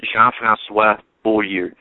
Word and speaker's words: Jean-François Beaulieu: The correct pronunciation is Jean-François Beaulieu. He's a French-Canadian. I'm a French-Canadian. So that's how you pronounce it.